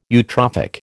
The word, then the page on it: eutrophic: American English